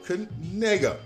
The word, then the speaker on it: Knigga: And they have a name called K-N-I-G-G-A. Noah Kanega.